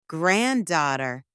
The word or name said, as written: granddaughter